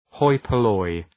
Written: hoi polloi

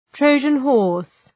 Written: Trojan horse

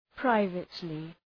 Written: privately

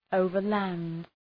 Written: overland

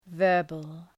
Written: verbal

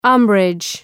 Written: umbrage

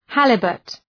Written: halibut

